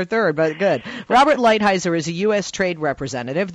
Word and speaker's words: Lighthizer: Is it Lighthizer or Lighthizer? – You got it, Lighthizer.